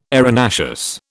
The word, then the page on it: arenaceous: British English